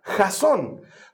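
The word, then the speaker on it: jazón (חזון): Esa palabrita de ahí, visión, viene del hebreo que se pronuncia jazón.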